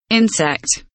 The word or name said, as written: insect